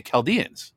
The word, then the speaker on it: Chaldeans: He said, FYI, the H in Chaldeans is silent. So what is it? The Chaldeans? Okay. Okay. I gotcha. Huh. I've always heard people pronounce it Chaldeans too. So there's been a lot of other people out there that have been saying it wrong.